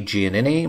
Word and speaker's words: Giannini: Giannini. My last name is spelled G-I-A-N-N-I-N-I.